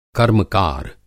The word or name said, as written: कर्मकार